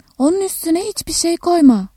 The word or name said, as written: koy-